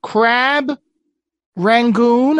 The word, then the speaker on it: crab rangoon: I'm going to try this crab rangoon. Am I not saying it right? – I think that's, you're saying it just right.